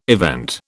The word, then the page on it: event: British English